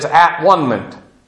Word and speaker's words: atonement: If you look at the word, you can even pronounce it as at-one-ment. And